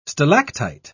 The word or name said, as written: stalactite